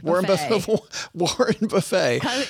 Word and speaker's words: Warren Buffett: Hey, coming up on Monday, everybody, we're going to talk about a big investor, a guy named, how do you pronounce this? Warren Buffett? Is that it? Warren Buffett.